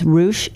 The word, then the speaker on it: Roush: Yes, and that was at Avalon Staples, and Pam Roush. I kept pronouncing her last name as Roush. It's Roush. Because I was spelling it R-O-U-S-H, but that, who cares about spelling?